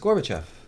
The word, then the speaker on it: Gorbachev: I also heard Gorbachev. – Yeah. – Which makes no sense. It's Gorbachev. – Gorbachev. Yeah, yeah. Gorbachev. – That's the way it's spelled anyway.